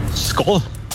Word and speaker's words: Skål: Skål. – Wow. Richtig? – Echt? – Ja. – Oh. – Du hast sogar richtig betont. – Oh. – Es wird Skål geschrieben, Skål, aber ausgesprochen.